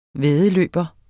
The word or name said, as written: væddeløber